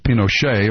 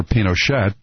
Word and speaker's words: Pinochet: Augusto Pinochet, or Pinochet, pronounced both ways, that overthrew the democratically elected government of Chile under Nixon and Kissinger.